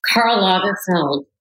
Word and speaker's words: Karl Lagerfeld: Just a few words on each of them, starting with Karl Lagerfeld. I don't know if I'm saying his name right. – Karl Lagerfeld, brilliant, impatient, and just so prolific and creative, and just such an incredible force, and generous too, really generous man.